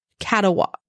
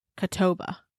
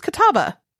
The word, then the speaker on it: Catawba: Catawa. Oh, no. Okay. Catawba. – No, no, that's how it's spelled, but this is how it's said. – Catawba. – This lady says Catawba.